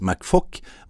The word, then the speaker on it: McFouk: Admiral Cedric McFock staves MCFOUK, men uttales McFock,